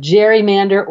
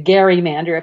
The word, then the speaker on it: gerrymander: So they came up with the term the gerrymander or the gerrymander, if you're going to pronounce it the way that some people say it should be pronounced.